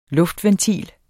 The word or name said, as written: luftventil